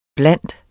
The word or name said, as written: blandt